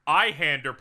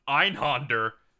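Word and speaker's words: Einhänder: Eyehander, pronounced Einhander, is a 2D Schmidways game.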